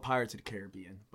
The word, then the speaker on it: Caribbean: It's pronounced Caribbean, first of all, you fucking weirdos.